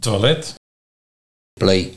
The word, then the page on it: Plee: Nieuw lekkerlands